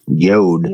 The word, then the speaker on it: Youde: So help me with this last name, because I've had a hard time pronouncing Yode? – It. Yode. Yode. Y-O-U-D-E.